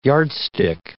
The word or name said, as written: yardstick